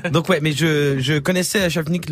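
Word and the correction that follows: Shayfeen: le, groupe de trappe marocaine. – Chayfine. – Chayfine. Bah, je, le connaissais mal donc. Fais attention avec les prononciations, c'est important grec.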